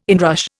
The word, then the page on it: inrush: Indian